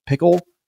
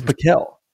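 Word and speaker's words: PicKell: because I thought it was like Pickle. It's Piquel. Piquel.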